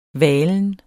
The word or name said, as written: valen